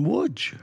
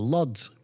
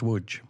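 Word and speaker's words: Łódź: Wodge, well, Lods, pronounced Wodge, or Wodge, right, written Lods, depending on... You know, if you're English, it's an L with a line through it, which is actually called a way in Poland.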